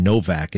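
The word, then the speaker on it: Nowak: One of the problems with this story is the name Novak. It's N-O-W-A-K, but it's pronounced Novak.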